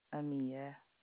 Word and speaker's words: Amia: Amia, A-M-I-A. – Oh, you got it right. – Yes, how about that? – Most people try to spell it A-M-E, and I'm like, no. Mia. – Oh. – Simply Mia with an A.